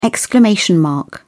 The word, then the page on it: exclamation mark: British English